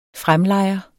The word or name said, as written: fremlejer